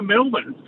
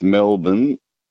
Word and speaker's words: Melbourne: The other night on overnights with Ashton, someone rang up and said that the way you pronounce Melbourne is it rhymes with falcon. and it's like Melbourne.